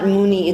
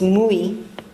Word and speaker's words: Mui: So in Bengali, it's not Muni, it's Mui. He pronounces this as Mui.